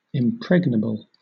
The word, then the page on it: impregnable: British English